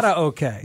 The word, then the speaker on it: karaoke: Say it right. – Karaoke. – Karaoke. God, mm-hmm. It just flows. It flows. – It's a soft roll of the R.